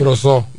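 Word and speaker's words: Brosseau: Los Leones del Escogido anunciaron en el día de ayer a Mike Brosul, así que se pronuncia, ¿verdad? – Eh, utility. – Sí. – Brosó. Brosó. Mike Brosó en Utility.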